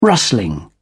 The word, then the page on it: rustling: British English